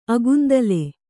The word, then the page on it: ಅಗುಂದಲೆ: agundale